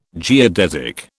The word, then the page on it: geodesic: British English